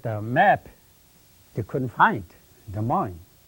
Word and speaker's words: Des Moines: And he said to me, this is Des Moines, Iowa. He said, here you got an S, you don't pronounce. Here you got an N. And Iowa is, the I there is a yo.